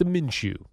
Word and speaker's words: Minshew: They should have given it to Minshew.